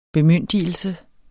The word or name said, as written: bemyndigelse